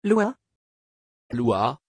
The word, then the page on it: lua: French